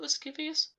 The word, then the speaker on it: lascivious: It was printed in 4 installments, all split up into a series of lascivious titles. Is that how you pronounce it? Lascivious? – Lascivious.